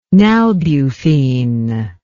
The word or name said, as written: nalbuphine